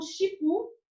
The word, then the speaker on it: Shippu: Então o Shipu, que é um aplicativo, ele é o se escreve S H I P P U.